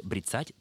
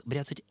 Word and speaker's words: бряцать: Вы правы, Евгений Александрович, в русском глаголе «брецать», так же, кстати, как и в производном от него существительном «брецание», ударение должно падать только на второй слог. И все современные орфоэпические словари подчеркивают, что ударение на первый слог «бряцать» — это неправильно.